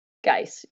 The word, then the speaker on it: Geis: But if you wanted the handle, it's my name, first name, last name, except for my first name is spelled M-A-A-A-A-I-A, Geis, G-E-I-S.